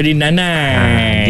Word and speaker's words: nanai: Yang ni nanai. Yang tadi tu dia ejar nahi-nahi, tapi H tu kadang-kadang gugur dia jadi dia sebut nai. Hak ni memang ejaan dia N-A-N-A-I.